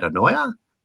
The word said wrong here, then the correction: Anoa'i: Anoa'i, that's better. Anoa'i wrestling family.